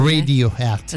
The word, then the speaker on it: Radiohead: Radiohead. – Esatto, si dice. Detto bene, così, Radiohead.